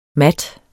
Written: mat

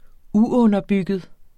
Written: uunderbygget

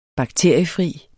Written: bakteriefri

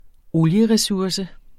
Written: olieresurse

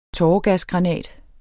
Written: tåregasgranat

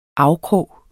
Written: afkrog

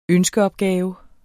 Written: ønskeopgave

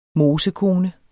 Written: mosekone

